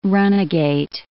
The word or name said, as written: runagate